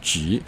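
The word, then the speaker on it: ji (桔): Tangerine is pronounced ji, and it's also it also means prosperity actually in Chinese.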